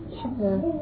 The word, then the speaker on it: cheveux: Cheveux.